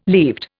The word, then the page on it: leaved: Indian